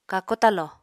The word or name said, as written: kako talo